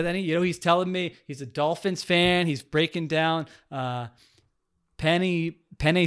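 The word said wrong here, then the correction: Penei: Sewell, is that, I pronounced that wrong. – Penai Sewell.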